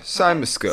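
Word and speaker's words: cymascope: which is called a cymoscope, right? Am I saying that right? It's C-Y-M-A-S-C-O-P-E. – Yeah, cymoscope.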